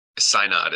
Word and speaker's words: Synod: Synod is how I pronounce that.